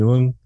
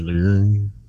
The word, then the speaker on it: Leung: Tony Leung. When you pronounce his name in Chinese, Tony Leung, there's like kind of an R sound in it, but like I can't replicate it because you know,